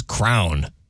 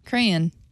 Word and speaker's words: crayon: This all kind of started with our good friend over here, who mispronounces the word crayon and says crown. Because why? – And what'd you say? How'd you say it? – Crayon. – Crayon. Okay, so you mispronounced the word as well. – There's a hint of Y in there. Crayon. – No. – Crayon. – C-R-A-N. – I would say there's two syllables. Crayon. Crayon.